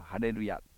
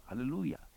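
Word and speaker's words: Hallelujah: こでハレルをクレッシェンドしてハレルーヤ、ハレルーヤ、まあこういうふうに理解していただいたらよろしいでしょう。カタカナで書いてハレルヤとか、あるいは歌謡曲なんかにもなんとかのハレルヤというようなねのはありますが、そうでなくハレルーヤ、ハレルーヤ、後ろに向かってクレッシェンドするような発音でございます。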